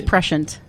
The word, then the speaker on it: prescient: Precious and... – it's prescient. – It's prescient. – It is. It's prescient.